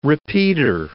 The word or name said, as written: repeater